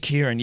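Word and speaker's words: Keighran: It is Kieran, yeah. It's certainly not spelt like Kieran. It's almost like a weird like Gaelic spelling.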